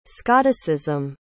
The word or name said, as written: scotticism